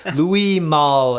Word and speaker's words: Louis Malle: So Louis Malle is the correct pronunciation.